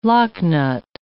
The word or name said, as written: locknut